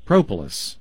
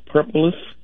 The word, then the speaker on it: propolis: P-R-O-P-O-L-I-S. Propolis. – Okay. – It's almost like the word Polish with a pro on the front of it.